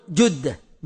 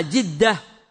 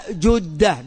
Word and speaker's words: جدة: يقول: كنا في الطائرة 7:30 يعني المغرب مساء فوق مدينة جدة، ونقول جدة بالضم في الجيم، هذا هو الصحيح. وأما جدة وجدة غلط، جدة هي أم الأب وأم الأم، وجدة من ضد القدم، والعوام ينطقون الجدة يقولون جدة جدة، والجدة هي ضد البلا ضد البالي القديم، وإنما هي جدة بضم الجيم، فمن نطقها بغير هذه الحركة فهو مخطئ.